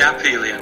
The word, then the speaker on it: aphelion: When a body is at its closest approach to the sun, and then it's APH, and I never knew if it's pronounced with an F sound. Is it aphelion or aphelion? – Yeah, it's usually aphelion.